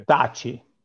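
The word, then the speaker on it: Thaçi: Tachi or Thaci? Am I pronouncing it correctly? I'm not sure. – Thaci was I think it's even interesting to explain, as you said, NATO was dropping bombs on Serbia and Kosovo.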